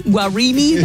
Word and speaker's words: Guarini: Guarini? That was always hard for me to say. – Yeah, that's how you say it.